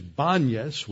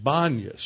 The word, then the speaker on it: Banias: You know, it was named for the Greek god Pan, but it's usually known as Banias with a B, because in Arabic you don't have a P sound, a hard labial. You just have a soft labial, so they pronounce it Banias.